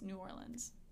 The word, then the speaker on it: New Orleans: Okay, so I was told the proper pronunciation is New Orleans.